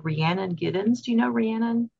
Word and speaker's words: Rhiannon: Rih- Rihanna. I don't know if I'm pronouncing it correctly. It's um, Rhiannon, um, like the um, Fleetwood Mac song.